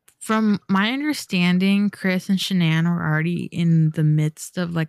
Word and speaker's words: Shanann: And the night that it happened, Shanann is the, I'm pretty sure that's how you say her name.